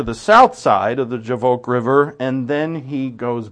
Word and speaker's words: Jabbok: or Jabbok, though the B is pronounced like a V in the Hebrew, Jabbok.